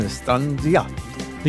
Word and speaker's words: Strangiato: So my number six is La Villa Strangiato, my favorite of the Rush instrumentals. Why? Your pronunciation. – is it bad? No, it's good. Strangiato.